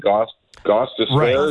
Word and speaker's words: Gostisbehere: but that's come is the Flyers defenseman Shane Gostisbehere.